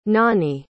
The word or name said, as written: nonie